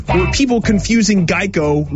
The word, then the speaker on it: Geico: Geico Gecko. – That's correct, hey. Brooke. The original idea was that a gecko could help people remember the name Geico, which often got pronounced like gecko.